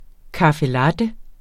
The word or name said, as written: latte